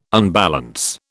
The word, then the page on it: unbalance: British English